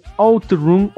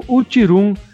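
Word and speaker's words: OutRun: A Taito lança o Bubble Bubble e a SEGA lança um otifíssimo jogo que merece um podcast, que é o Outroom ou Utirum, como muita gente já ousou pronunciar o jogo, né? – Eu nunca ouvi essa pronúncia.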